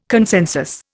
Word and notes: consensus: Indian

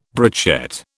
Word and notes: brochette: British English

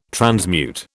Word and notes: transmute: British English

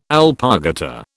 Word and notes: alpargata: British English

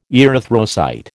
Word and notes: erythrocyte: American English

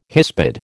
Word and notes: hispid: American English

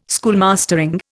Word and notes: schoolmastering: Indian

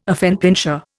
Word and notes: affenpinscher: Indian

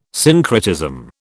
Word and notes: syncretism: British English

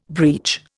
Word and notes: breech: Indian

